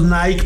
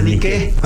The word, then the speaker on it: Nike: O sea, que nosotros le decimos Nike por la marca. – Sí. – Pero se pronuncia Nike.